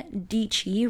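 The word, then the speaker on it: Deetschy: But the spotlight that I wanted to do for today's episode is for Sarah Deechy, rhymes with peachy.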